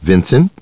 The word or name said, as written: Vincent